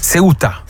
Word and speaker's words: Ceuta: Ceuta.